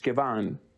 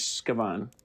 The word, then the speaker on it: Xkeban: So it's Xkeban. The X sound in Mayan language is pronounced sh. So if I'm going to say the name right, it's Shkiban. I mean, I might be pronouncing it with a different influx, but the X makes an SH sound.